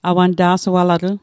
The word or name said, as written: awanta'sualatl